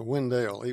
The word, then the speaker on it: Wendell: And by the way, it's pronounced Wendell, even though it was named after Oliver Wendell Holmes.